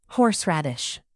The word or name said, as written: horseradish